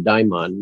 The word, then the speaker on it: daimon: and I pronounce it daimon, there are different pronunciations,